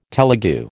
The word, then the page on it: Telegu: American English